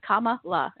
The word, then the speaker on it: Kamala: Yeah, it's Kamala, if that helps Kamala. – You go for it. Yeah. – Okay, Kamala Harris. I want to make sure I'm saying it right.